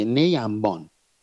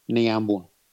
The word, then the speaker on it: نیمبان: در واقع نی و انبان هست دیگه، نیمبان، ولی خب به اصطلاح توی بوشهر با اون لهجه بوشهری ما میگیم نیمبون.